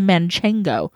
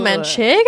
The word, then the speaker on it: manchego: I'm actually going to be a manchego. – A little manchego.